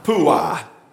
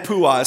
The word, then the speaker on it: Puah: The emphasis is on the end, on the awe. All right, say it with me. Shiprah, Puah. Shiprah and Puah.